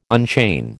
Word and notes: unchain: American English

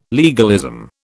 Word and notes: legalism: British English

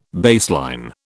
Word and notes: baseline: British English

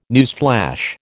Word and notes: newsflash: American English

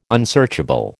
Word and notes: unsearchable: American English

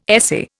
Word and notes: essay: Indian

